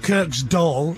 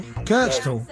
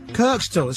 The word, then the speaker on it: Kirkstall: Not Kirk's doll, Kirkstall. Kirkstall, it's got... – Kirkstall. – Yeah. If you're from Leeds, you don't pronounce the word the... Okay, so you put a T in, so Kirkstall.